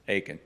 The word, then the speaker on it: Achan: Achan.